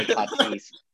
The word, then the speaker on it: Tatis: Padres, Tatis, the star Tatis, Fernando Tatis, as his actual name is pronounced.